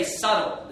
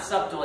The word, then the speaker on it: subtle: It's the same way with the words, you know, it's not subtle, it's subtle, because the B is silent, right? When you read the word S-U-B-T-L-E in the Bible, that B is silent.